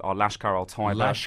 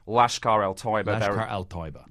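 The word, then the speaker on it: Lashkar-e-Taiba: Well, the key suspects are Lashkar al-Taiba. – Lashkar al-Taiba.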